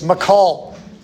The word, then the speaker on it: Michal: Pronunciation, I learned this, is Michal.